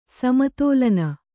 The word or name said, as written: ಸಮತೋಲನ